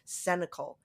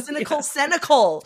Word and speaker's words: Cenacle: Cenacle. All right. It's funny that a group of writers is a cynical, but it's spelled wrong. – Cynical. Yeah. Cenacle.